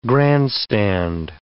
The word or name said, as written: grandstand